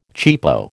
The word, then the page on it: cheapo: American English